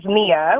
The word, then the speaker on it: Nia: Well, if you're going to say that, then you have to say my name right, which is Mia with an N, but it's, which is perfectly fine.